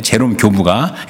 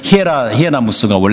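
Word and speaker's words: Hieronymus: 근데 그 당시에 서방교회 4대 교부에 들어가는 제롬이라는 사람이 있는데, 제롬 교부가, 이게 영어식 발음입니다. 원래는 히에라무스가 원래는 발음인데, 제롬이, 로마 멸망할 때 이렇게 얘기했습니다.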